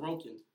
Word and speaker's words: Sorokin: You have to emphasis a certain syllable. – Sorokin. – A certain syllable. – Sorokin. – Yep. – There you go.